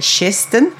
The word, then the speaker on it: Kirsten: And one of the characters, I just love her, is it's written as Kirsten, but the pronunciation is apparently Shisten.